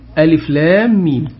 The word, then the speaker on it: ألف لام ميم: طيب لكن الحروف المقطعة ألف لام ميم هذه حروف مقطعة لا تنطق هكذا، لا يقال ألم ذلك الكتاب لا ريب فيه، إنما تقال ألف لام ميم، هكذا حتى ورد في الحديث من قرأ حرفا من كتاب الله فله إيه؟